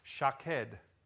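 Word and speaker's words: shaked (שָׁקֵד): And that is that the word almond is pronounced shaked. Shaked. And the word watching that God uses in verse 12 is pronounced shoked. Shoked and shoked.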